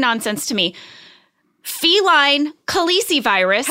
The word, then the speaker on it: Khaleesi: It's called Khaleesi virus.